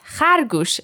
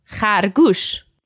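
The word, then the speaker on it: khargush (خرگوش): And an animal that rhymes with that is khargush, and that means rabbit. Khargush.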